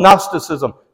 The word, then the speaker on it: Gnosticism: I asked Jamie to put, I want you to see how that's spelled, Gnosticism, silent G.